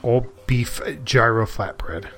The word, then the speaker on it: gyro: Pronounced gyro. – It's gyro, actually.